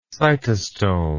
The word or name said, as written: cytostomal